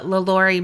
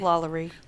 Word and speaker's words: LaLaurie: As an American Horror Story fan, I had to make one of my first stops the LaLaurie Mansion. How do we say that you actually say it? – LaLaurie. – LaLaurie, yeah.